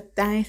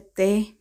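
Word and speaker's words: TE (태): Pero no, en coreano se pronuncia TAE, se pronuncia TE.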